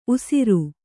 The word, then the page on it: ಊಷರ: ūṣara